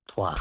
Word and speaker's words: toit: So does toit mean roof? – It's pronounced toit.